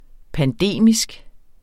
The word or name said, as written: pandemisk